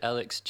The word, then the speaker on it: ALCS: So my Bandcamp is called, I release everything under Alex, which is spelt ALCS. And I'm sure if you just type that into Bandcamp, you'll find it. I really wanted Alex G because that my last name's Guthrie, but obviously there's Alex G already out there.